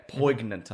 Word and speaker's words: poignant: Poignant, I think it's pronounced, Mason.